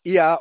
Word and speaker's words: Yah: This diphthong is pronounced yah or yah, which is the short form of the Heavenly Father's name, Yahweh.